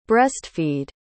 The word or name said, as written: breast-feed